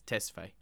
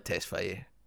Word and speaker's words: Tesfaye: There's two pronunciations, basically. There's a westernized version of pronunciation and there's the Ethiopian original. He says Tesfaye. – It's definitely Tesfaye.